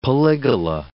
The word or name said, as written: polygala